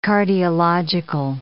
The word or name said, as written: cardiological